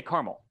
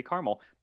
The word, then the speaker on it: caramel: I say caramel. – Me too. – Because, yeah, because there are places, yeah. So there are, you know, different regional dialects and everything, and that's just how we say caramel, but it should be caramel.